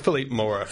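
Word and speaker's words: Philippe Mora: Am I saying that right? – I think it's Philippe Moira. – Philippe Moira, yeah.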